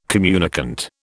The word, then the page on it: communicant: British English